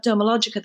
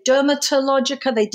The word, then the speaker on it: Dermalogica: They'd say Dermatologica. We would say the tea is silent. There is no tea, but people just somehow insert it.